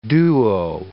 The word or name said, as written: duo